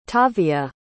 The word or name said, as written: tavia